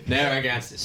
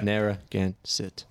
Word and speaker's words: Narragansett: And I never knew how to pronounce it, and now I do. So Narragansett.